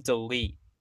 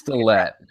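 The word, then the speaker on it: DeLaet: First guy being Graham DeLitt. Am I even saying his name right? – I think it's DeLitt. – DeLitt? It's like DeLitt.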